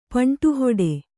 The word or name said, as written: ಪಂಟುಹೊಡೆ